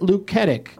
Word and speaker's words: Luketic: That was directed by Robert Luketic.